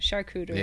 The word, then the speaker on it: charcuterie: I want a charcuterie board. – Yeah. Oh, we have it, did I say it right? – Charcuterie. – Yeah. Charcuterie.